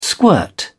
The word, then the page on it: squirt: British English